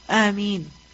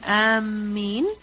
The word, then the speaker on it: Ameen: And what is the correct way of pronouncing the word Ameen? Is it Ameen with a shadda on the meem? No. If you put a shadda on the meem, Ameen, then what does that mean? Ameen al-bayt. Those intending.